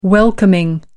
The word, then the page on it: welcoming: American English